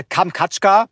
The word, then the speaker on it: Kamtschatka: Und zwar ist ja Kamtschatka, heißt, wenn ich es so richtig ausspreche, genau.